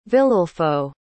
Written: viliulfo

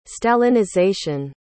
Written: stalinization

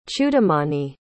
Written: chudamani

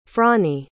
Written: fronnie

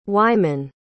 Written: wyman

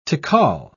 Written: tical

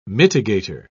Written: mitigator

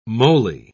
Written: moly

